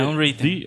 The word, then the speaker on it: Unwritten: É, Unwritten.